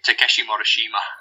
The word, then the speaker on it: Takeshi Morishima: Takeshi Morishima.